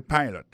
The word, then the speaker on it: pilot: Pilot là, c'est même mot pilote là, il veut dire E-A-D-L-I, il écrit P-I-L-O-T. Alors, il s'est prononcé I dans le cas. Alors, et puis, dans le mot pilot, là, tout, après accent tombé sur le premier syllabe.